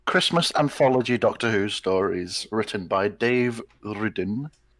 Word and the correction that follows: Rudden: I don't think that's how you pronounce it. – Rudden. – Rudden.